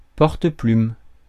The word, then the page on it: porte-plume: [pɔʁ.tplym]; France